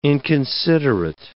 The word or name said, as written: inconsiderate